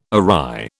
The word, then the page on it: awry: British English